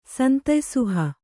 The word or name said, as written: ಸಂತಯ್ಸುಹ